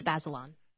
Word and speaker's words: Bazelon: This hour we're going to be talking to Emily Bazelon. Is that how you pronounce it? Yeah? – I believe it's Bazelon.